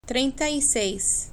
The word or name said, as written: trienta y seis